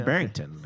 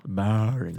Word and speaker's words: Barrington: I mean, you live in Barrington. I mean, come on. Let's go. Let's be honest. – You pronounced it wrong, though. – Barrington.